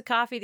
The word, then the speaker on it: Ko-fi: Yeah, it's spelled K-O-F-I. It's a little misleading. – Yeah. – We say coffee.